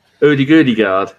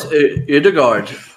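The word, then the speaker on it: Ødegaard: Udegudegard. That's how you say it. – No, it's Udegard.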